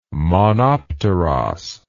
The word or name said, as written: monopteros